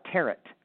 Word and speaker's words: tarot: And he goes, you realize no one pronounces that right. It wasn't popular then, Susan. He said, they call it tarot. They don't know not to pronounce the T.